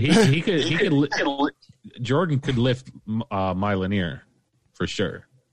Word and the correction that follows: Mjolnir: No, it's Mjolnir.